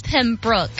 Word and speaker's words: Pembroke: Because it's Pembroke.